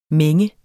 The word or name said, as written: mænge